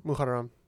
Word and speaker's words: Muharram: Muharram? – Muharram.